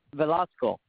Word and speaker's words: Velazco: J-O-S-E? – Yes. – Okay. And what's your last name? – Velasco. – Can you spell it, please? – V-E-L-A-Z-C-O.